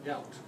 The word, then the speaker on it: Geat: The reason I ask is that when Beowulf is introducing himself to the Danes, he's a Geat. Is that how you say it? – Yaut. – What? – Yaut. – Oh. – He's a Yaut. – It looks like Geat.